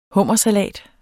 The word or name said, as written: hummersalat